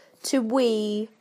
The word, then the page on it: to wee: British English